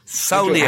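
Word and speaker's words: samhlaíocht: Nine nil ein kulu san solicht. – Sauliacht. How do you pronounce it? Sauliacht.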